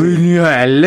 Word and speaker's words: Buñuel: Bene, detto Buñuel, che si scrive con l'N con l'umlaut, quindi sarebbe sì, Buñuel, esatto.